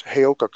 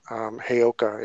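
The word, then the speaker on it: Heyoka: It's Hayoka coach. Hayoka is how you pronounce it.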